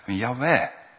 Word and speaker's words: Yahweh: En vroeger zeiden we dan, dat betekent Jehovah. Dat zeggen we tegenwoordig niet meer. We geloven vandaag dat Yahweh betekent. Dat we het zo ook mogen uitspreken. Yahweh.